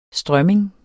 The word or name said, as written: strømming